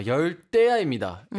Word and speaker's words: 열대야: 이런 거 같긴 한데 아, 열대야입니다. It's right, pronounced right. with like a double T.